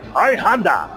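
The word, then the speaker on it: Einhänder: Y me refiero pues a Einhander, que en la correcta pronunciación es ¡Ay, Handa! Algo así.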